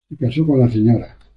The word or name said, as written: Sra.